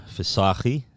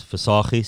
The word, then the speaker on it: Visagie: I like the way you pronounce my surname, Visagie. So, yeah, so it's Visagie. So, it's pretty on point. So, thanks for that. Thanks for the efforts. – You just didn't see the 3 hours of me practicing last night. – Yeah, no, I think you guys, you don't actually pronounce the chi.